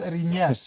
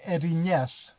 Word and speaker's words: Erinyes: Yeah, the Furies were called Erinyes in Greek. – So could you say that one more time? – Erinyes.